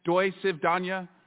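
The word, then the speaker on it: doi civ dania (до свидания): Rigor mortis, lights out, curtain drop, mic drop, peace, adios, sayonara, au revoir, or a language that you all understand, doi civ dania. Did I say that right, Mr. Parnas? – Yes.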